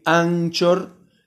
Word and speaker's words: Anchor: Así que les deseo que tengan un gran día y no se olviden de mandar, de bajar la aplicación Encore Anchor en español así se pronuncia, se escribe Anchor, tal y como la pronuncia, CH, Anchor, eh, bájenla y por ahí se pueden comunicar con nosotros.